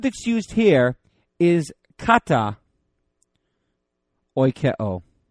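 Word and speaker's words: Oikeo (οἰκέω): Oikeo. O-I-K-E long O. I guess is how we would transliterate it. Oikeo.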